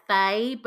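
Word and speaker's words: fáith: Portents of death, not dissimilar to later fetch traditions, are found in early Irish literature and are associated with the old Irish term faith, which is pronounced like fey, but written as faith, and he's said to mean seer.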